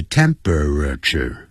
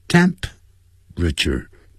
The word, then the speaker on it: temperature: 这本来是四个音节 temperature，但实际老外念成三个音节 temperature，这样。